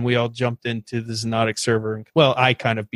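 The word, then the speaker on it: Xonotic: And it is spelled with an X. So it's X-O-N-O-T-I-C. So you pronounce it Zenotic, but it's spelled with an X.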